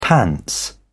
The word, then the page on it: pants: British English